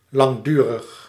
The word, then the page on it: continuel: French